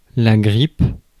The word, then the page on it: grippe: [ɡʁip]; France